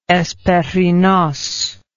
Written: hesperinos

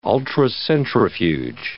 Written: ultracentrifuge

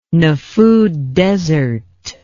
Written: nefud desert

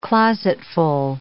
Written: closetful

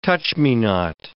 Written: touch-me-not

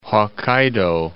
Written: Hokkaido

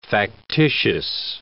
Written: factitious

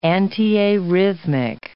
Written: antiarrhythmic

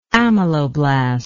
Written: ameloblastic

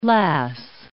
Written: lass

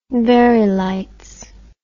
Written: verey lights

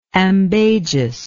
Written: ambagious

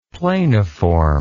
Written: planiform

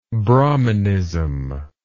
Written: brahminism

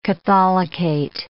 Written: catholicate

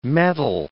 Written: mettle

